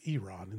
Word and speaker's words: Iran: I learned mm-hmm. from you just now that it's pronounced Eeeran instead of Iran.